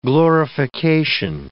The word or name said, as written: glorification